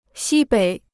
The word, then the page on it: 西北: xī běi